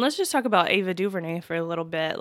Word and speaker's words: DuVernay: Did I say her name right? Ava DuVernay. Mm-hmm. Not Ava DuVernay.